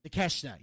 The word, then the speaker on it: Takeshita: Takeshita? – Takeshita, yeah.